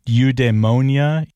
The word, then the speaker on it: eudaimonia: It's like eudaimonia. – Close. – E-U-D-A-I-M-O-N-I-A. I've seen this word. – You pronounced it perfectly.